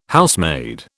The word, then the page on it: Housemaid: British English